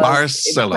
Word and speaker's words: Barcelona: And I'm going to do the horrible thing that we always do, which is correct people that it's actually Barcelona because Barcelona. – It's pronounced in a Catalan accent, not a Spanish accent.